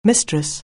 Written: mistress

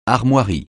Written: armoiries

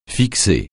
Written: fixer

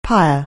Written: pyre

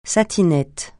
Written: satinette

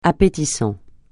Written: appétissant

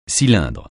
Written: cylindre

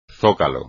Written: zócalo

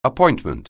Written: appointment

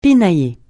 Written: pinailler

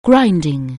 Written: grinding

